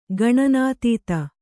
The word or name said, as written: ಗಣನಾತೀತ